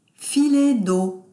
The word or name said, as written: filet deau